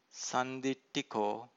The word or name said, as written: sanditthiko